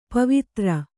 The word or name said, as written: ಪವಿತ್ರ